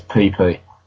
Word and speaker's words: Phi Phi: Okay, alright, there's an island in Thailand that I know of that I really want to visit, so I go there, and it's PP. It's spelled P-H-I-P-H-I.